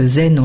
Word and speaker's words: Zen: Vous avez, par exemple, les maîtres bouddhistes qui s'expriment, par exemple, ceux du Chan, qu'on prononce Zen en japonais, donc sont des gens qui ne font que s'exprimer par oral.